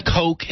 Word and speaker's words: Koch: And Coke is spelled K-O-C-H, as in the Koch brothers.